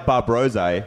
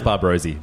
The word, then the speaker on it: Bar Brosé: I think you say a bit, a, a bit like Bar Brosé, eh? I think it's restaurant Amuse. Right. it's pronounced Barbrosy, by the way. Barb Rosie.